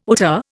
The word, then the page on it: hauteur: Indian